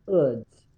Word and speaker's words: Eudes: I say Eudes because that's the French pronunciation. And you know those French, their letters correspond to how that's pronounced, because his name is spelled E-U-D-E-S, but it's pronounced Eudes, at least according to pronunciation on Google.